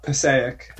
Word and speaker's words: Passaic: Oh, it's Passaic.